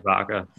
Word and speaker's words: Ibaka: We struggled against the Heat, the Raptors, Serge Ibaka, I think it's how you say his name, is not. – Serge Ibaka, yeah.